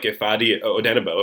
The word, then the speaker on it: Ifeadi Odenigbo: And if you don't have him – now, I do think we both like Ifeadi Odenigbo. Am I saying his name right?